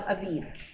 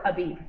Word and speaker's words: Aviv: So, Nisan is the Babylonian word for the month, but it actually is the month of Aviv, or Abib, depending on how you say it.